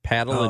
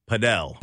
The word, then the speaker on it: Padel: For Roaming St. Louis, Paddle and Pickle Club? – Padel.